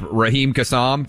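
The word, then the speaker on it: Raheem Kassam: Rahim Kassam, did I get that right? – You nailed that one.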